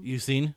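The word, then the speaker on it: Eusine: At the end, we get to see how do you say his name? Yusin? – Yusin.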